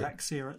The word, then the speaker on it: bigorexia: How do you pronounce it? – Bigorexia, it's known as.